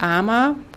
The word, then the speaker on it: AMA: Ama. Oft wird das A am Ende gar nicht ausgesprochen hier, dass man nur Arm sagt. Ja, es kommt auch vor. Aber wir kennen es unter AMA, A-M-A.